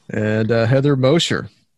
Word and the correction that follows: Mosher: I say Mosher. More like a Z, but okay. – Yeah. – Okay. All right. Mosher.